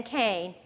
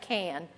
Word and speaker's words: Cain: Prompted, actuated by faith, Abel brought God a better and more acceptable sacrifice than Cain. Boy, see, I spelled his name wrong. It's not Can. Than Cain.